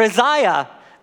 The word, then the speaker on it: Isaiah: Isaiah goes in, or Isaiah, proper way to say it.